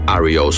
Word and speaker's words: REO: Al principio, sus miembros le decían Rio, pero con el fin de diferenciarse de la marca de camiones, decidieron usarlo en forma de iniciales, lo que dejó el grupo como REO Speedwagon y como lo estamos pronunciando correctamente aquí en el programa.